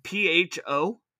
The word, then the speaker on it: pho: Yeah, that's pronounced pho. – What? – That's pronounced like pho, P-H-A.